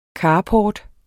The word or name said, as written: carport